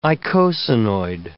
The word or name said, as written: eicosanoid